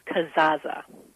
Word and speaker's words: Kazaa: It's Kazaa. – Kazaa. You pronounce both Z's. – Yes, okay. – You do. Kind of like pizza, I was told.